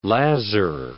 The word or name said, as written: lazar